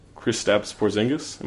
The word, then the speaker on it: Kristaps Porzingis: Yeah, I mean, and then after that, you have D'Angelo Russell or Kristaps Porzingis.